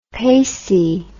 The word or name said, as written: pacy